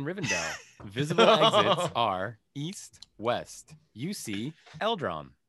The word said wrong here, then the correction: Elrond: Elrond? – Did I say it wrong? Eldron. No, still not. There's no D at the beginning.